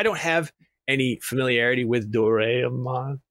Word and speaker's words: Doraemon: doraemon uh, Doraemon.